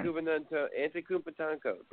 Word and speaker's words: Antetokounmpo: No, man, let me just tell you a little something about Giannis Gampadukuduswo. Man, dude, the guy. – Oh, please tell me you can finally pronounce his name correctly. Let's try that again. – Antetokounmpo, bro, and Team Pompo.